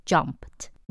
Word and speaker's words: jumped: ed 要读 t，因为 jump 尾音是无声 p，记得吗？无声配无声 jumped。